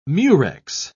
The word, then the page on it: murex: British English